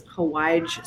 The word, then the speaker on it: Hawaij: I love the my all-time favorite spice blend for pickles is Hawaij spice. It's a Yemenite, almost curry-like spice blend. And it's spelled like Hawaii, but it has a J on the end. So it's H-A-W-A-I-J.